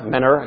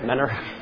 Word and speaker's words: Mentor: Mentor, I guess you're supposed to say Mentor. I'm learning how to say it, Mentor, not Mentor.